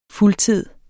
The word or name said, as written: fuldtid